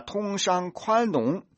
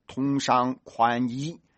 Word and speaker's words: 通商宽容: 把通商宽容、字正腔圆、稳稳当当的读成了通商宽衣，从而成为全中国和全世界的笑柄。